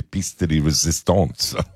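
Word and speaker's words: pièce de résistance: This is the piste de resistance, if I can say it that way.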